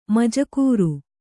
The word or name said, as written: ಮುಜಗರ